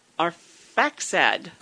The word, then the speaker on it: Arphaxad: Two years after the flood, when Shem was 100 years old, he became the father of Arf, Arf, Arphaxad. – Go ahead and spell it. It's an unusual name. – A R P H a X A D.